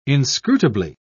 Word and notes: inscrutably: British English

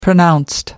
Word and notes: pronounced: American English

